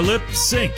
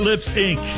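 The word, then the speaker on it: Lipps Inc: They are Lip Sync, not Lips Inc. Just wanted to make that clear how you pronounce that group.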